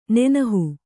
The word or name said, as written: ನೆನಹು